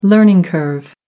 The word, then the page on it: learning curve: American English